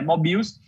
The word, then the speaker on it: Mobills: Hoje a gente está para Android, iOS e também para o computador, é só entrar no site, procurar por Mobills, Mobiles, escreve mais ou menos qualquer coisa que você vai encontrar aí, mas a pronúncia certa é Mobills.